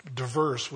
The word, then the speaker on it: divers: I think the Old King James uses the word, they pronounce it divers, but it's diverse would be the idea there.